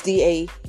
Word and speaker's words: Damar: DeMar, is that his name? Did I spell his name? Say his name? It's DaMar. I know him, but I didn't say his name right.